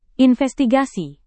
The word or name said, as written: investigasi